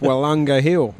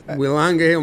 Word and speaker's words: Willunga: Yeah, your hip surgeon on Twitter pointed out that it's Willunga Hill, not Willunga. – Thank you for that, Mr. Banks. – Willunga Hill, mate.